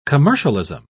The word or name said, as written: commercialism